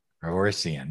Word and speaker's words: Paroissien: Paroissien, there we go.